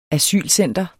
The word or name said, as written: asylcenter